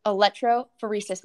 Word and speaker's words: electrophoresis: Electrophoresis machine.